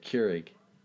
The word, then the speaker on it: Keurig: Keurig.